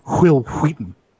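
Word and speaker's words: Wil Wheaton: She at least pronounced his name correctly. It's Will Wheaton.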